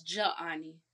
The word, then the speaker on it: Jaani: It's pronounced like Johnny. It's Jaani.